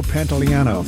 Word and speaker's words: Pantoliano: And I'm really excited about, even though I'll never go to this thing, Joey Pantalonio. Am I saying his name right? I'm not saying his name properly, am I? Joey Pants. You know this guy? Joe Pantoliano, thank you, that's it.